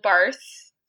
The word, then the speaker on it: Barthes: It comes from a 1967 essay by the French literary critic Roland Barthes, spelled with an S on the end because he's French.